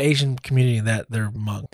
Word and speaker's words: Hmong: And these pronounced Hmong. – Hmong? Yeah. Oh, okay. I didn't know that at all.